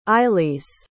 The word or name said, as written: ailis